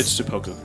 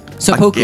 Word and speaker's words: Seppuku: It's Seppuku. – Seppuku.